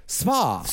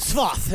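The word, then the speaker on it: Sfath: Svoth. – Svoth. It's important to put all of the letters together in Swiss. – How do you Svoth.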